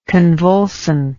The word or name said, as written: convulsant